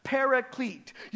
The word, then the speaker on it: paraclete: The term is parakletos, which transliterates into English and is often pronounced as paraclete.